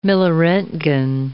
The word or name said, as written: milliroentgen